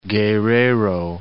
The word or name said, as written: Guerrero